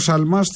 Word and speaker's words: Σαλμά: Σαλμά, συγγνώμη για το, για το γιώτα που εμφυλοχώρησε στην προφορά μου.